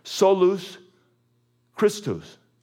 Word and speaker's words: solus Christus: So in the original Latin, it's pronounced this, solus Christus.